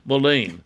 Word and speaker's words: mullein: M-U-L-L-E-I-N, mullein.